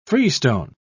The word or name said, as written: freestone